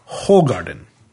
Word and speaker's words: Hoegaarden: The name, you've seen it before. You probably pronounced it Hoegaarden. It says on the labels, or it says on the boxes, to pronounce it Hoegaarden. But I believe, and I may be wrong here, but I believe the correct pronunciation is probably more like Hoegaarden. So that is probably how it's properly pronounced.